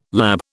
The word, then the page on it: lab: British English